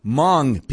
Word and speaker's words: Hmong: Hmong people. That's spelled H-M-O-N-G, and the H is silent.